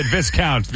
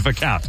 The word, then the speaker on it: Viscount: Also, I'm not apparently supposed to pronounce the S in Viscount. I said Viscount. People say it's Viscount.